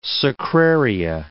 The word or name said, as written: sacraria